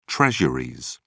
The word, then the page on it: treasuries: British English